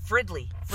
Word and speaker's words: Fridley: Yeah, Fridley.